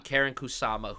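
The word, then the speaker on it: Karyn Kusama: And that's a really impressive feat on, I hope I don't butcher her name, Karen Kusama. – Yes. – Is that her name? Yeah. Karen Kusama, who directed the hell out of this.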